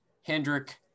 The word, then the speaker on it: Hendrick: There's no S at the end of Hendrick. It's pronounced Rick Hendrick.